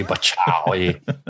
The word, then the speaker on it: Bacchae: A Bacchae is a K, Bacchae.